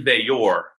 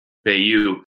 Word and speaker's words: Veillor: One's a January enrollee, Christian Veillor. Now, according to Dwight Gault, that's how you pronounce his name. We've been pronouncing it Bayou.